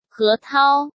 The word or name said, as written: He Tao